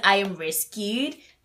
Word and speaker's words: IMRSQD: um, his name is I am rescued, but like it's spelled like I M R S Q D, right?